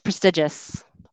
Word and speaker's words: prestigious: So it's prestigious. Prestigious is the pronunciation for this word.